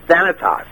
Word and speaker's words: Thanatos: Actually, I went to an online dictionary, and that's not how they pronounced it. – Really? – Yeah, I tried several of them, because some of them you can listen to it, and everybody pronounced it Thanatos.